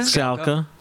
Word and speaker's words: Skalka: It says it's pronounced like Alka-Seltzer. So how would that be? Stuart Skalka? – Skalka.